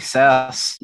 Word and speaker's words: Sass: So my name's Omri Sass, not spelled like software as a service.